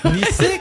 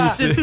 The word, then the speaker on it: Nysay: Nizé quoi. Nizé. Mais Nizé ça s'écrit bizarre avec un Y, c'est ça? – N-Y-S-A-Y. – N-Y-S-A-Y. – N-Y-S-A-Y.